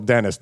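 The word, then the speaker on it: Denys: It's spelled with a Y. D-E-N-Y-S. – Okay. – I mean, it sounds a little contemporary or modern. – Dennis. – I think it's still Dennis, though.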